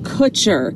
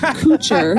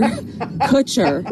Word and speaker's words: Kutcher: Ashton Kutcher, not Kutcher, Kutcher.